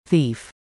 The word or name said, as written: thief